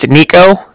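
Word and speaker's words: Niko: It's Nico. That's N-I-K-O. – Okay. – And then Cardiff is spelled C-A-R-D-I-F-F, as in Frank Frank.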